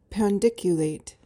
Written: pandiculate

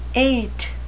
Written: ait

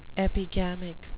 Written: epigamic

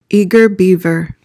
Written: eager beaver

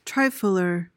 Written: trifler

